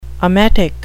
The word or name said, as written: emetic